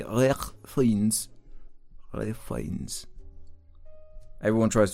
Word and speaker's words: Ralph: Yeah, it's pronounced Rafe. It's pronounced Rafe. He's right. R-A-F-E. – Ruben just got fucked. – Yeah, it's because it's spelled Ralph.